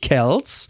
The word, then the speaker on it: Kelce: Und hier der Travis Kelce, ne, der Freund von Taylor Swift, also toll. – Heißt es nicht Kelce? – Nein, das ist ja auch, also er heißt ja wirklich Kelce.